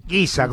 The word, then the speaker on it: Giza: Giza, como Giza. Si fuera, en vez de, como si le pusiéramos una U y una I, como de guiso, pero Giza.